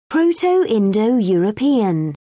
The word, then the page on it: proto-indo european: British English